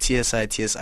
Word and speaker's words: Tsitsi: Yeah, and Tsitsi is spelled Tsitsi with no space. A lot of people say T and then they have a space and then the other T. I'm like, it's one name.